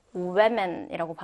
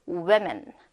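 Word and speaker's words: women: 자, 근데 여성 여러 명일 때는요. 우에맨이라고 발음해요. 우에맨. 자, 방금은 우먼. 여기는 우에맨이라 그랬죠? 그러니까 좀더위 발음이 납니다.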